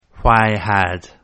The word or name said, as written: why had